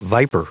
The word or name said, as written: viper